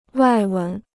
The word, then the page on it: 外文: wài wén